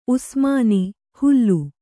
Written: ಹಲಿ

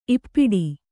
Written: ಈಪಾಟಿ

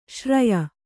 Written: ಶ್ರಾಯ

